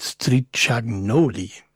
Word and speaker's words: Stricagnoli: In dem Fall die Version von Luca Stricagnali. Nee, wie heißt der Moment? Stricagnoli. Sorry.